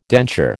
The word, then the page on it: denture: American English